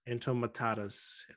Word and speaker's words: entomatadas: Entomatadas.